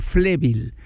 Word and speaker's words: flébil: ¿Qué cosa es algo flébil? Flébil con acento en la E.